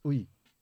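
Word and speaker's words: Uyi: So it's actually pronounced Ui.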